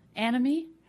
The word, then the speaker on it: anime: It's called Anime. – Oh, that's the story. – Am I pronouncing it right? – Yeah, you are. – Okay. Yeah, good. – Yeah. – What's it called? – Anime.